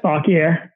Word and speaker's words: Fauquier: Fauquier.